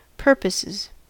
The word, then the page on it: purposes: American English